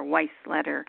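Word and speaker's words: Weisleder: And also, Stan's last name is spelled W-E-I-S, like Sam, L-E-D-E-R, Weisletter.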